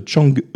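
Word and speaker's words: Chang'e (嫦娥): ces émissions m'auront au moins permis de connaître enfin la bonne prononciation de Chang'e et non pas Chang'e comme je l'avais dit dans les précédentes chroniques.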